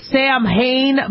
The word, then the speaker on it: Samhain: It's spelled Samhain, but I guess it's Samhain.